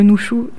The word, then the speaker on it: Nushu: Je ne sais pas non plus exactement si je le prononce bien, mais en tout cas le Nushu, c'est comme ça que ça se prononce.